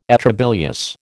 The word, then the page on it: atrabilious: American English